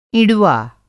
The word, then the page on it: इँडुआ: Hindi